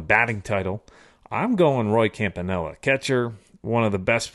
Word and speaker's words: Campanella: Campanella.